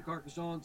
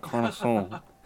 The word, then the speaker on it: Carcassonne: Carcassonne, some of these other, German board games that have been reimported back here.